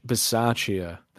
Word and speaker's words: Bisaccia: Rich Bisaccia, that's how we pronounce it.